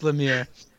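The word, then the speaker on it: Lumière: The answer is Lumiere, correct? Is that the right pronunciation? – Yeah, I couldn't pass that one over here. Yeah, it's Lumiere.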